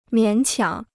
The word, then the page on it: 勉强: miǎn qiǎng